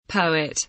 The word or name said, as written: poet